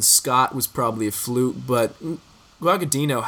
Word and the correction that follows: Guadagnino: how, how, how do I pronounce it? Is, is it Guagadino? – however Guadagnino. I Guadagnino.